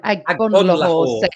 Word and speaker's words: Agbonlahor: And I'm going to talk about Martinelli in a second because I want to talk about what Gabby Abla, I can't say his name, Agbonlahor said.